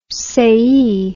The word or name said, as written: sayee